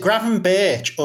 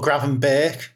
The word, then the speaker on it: Gravenberch: Well, first of all, is it Gravenberch or Gravenberch? – The Dutch would say Berch.